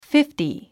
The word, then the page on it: fifty: fífti; フィフティ